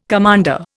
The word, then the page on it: commander: Indian